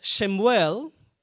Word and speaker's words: Shemuel: But in Hebrew, it's Shemuel, which means asked of God.